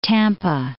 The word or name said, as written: Tampa